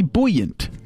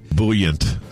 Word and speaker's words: buoyant: Say that word again. Buoyant? – Buoyant? I think you're saying it wrong. Buoyant. – Buoyant? I think it's buoyant. – Buoyant. – Like buoyancy? – Like it's a buoy. The thing that floats in the lake? – Right.